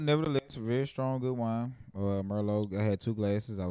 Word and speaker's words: Merlot: Merlot. – Thank you for that. – Merlot. The tea is silent.